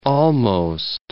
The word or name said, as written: almost